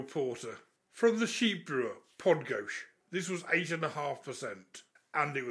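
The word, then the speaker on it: Podgórz: P-O-D-G-O-R-Z. It's pronounced Podgosh.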